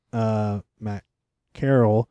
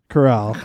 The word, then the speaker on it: Corral: But I will say, Matt Carroll. – Corral.